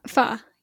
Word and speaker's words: pho: Someone says go out to eat for ramen, or how do you pronounce that? Pho? – Pho, yeah.